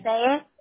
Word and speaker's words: Passaic: Passaic.